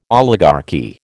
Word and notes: oligarchy: American English

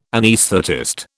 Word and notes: anaesthetist: British English

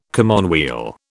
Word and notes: commonweal: British English

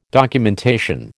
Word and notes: documentation: American English

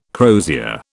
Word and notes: crozier: British English

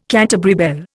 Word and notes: Canterbury bell: Indian

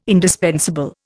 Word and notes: indispensable: Indian

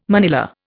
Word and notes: manilla: Indian

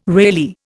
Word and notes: Reilly: Indian

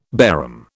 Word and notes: Bairam: British English

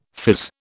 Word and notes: phiz: British English

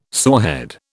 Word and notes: sorehead: British English

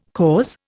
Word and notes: cause: Indian

